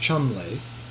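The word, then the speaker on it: Cholmondeley: Thoreau learned about the Bhagavad Gita because a friend of his from England, who has a very long name, but it's pronounced Chumley, Thomas Chumley, sent him a whole box of the classics of India translated into English.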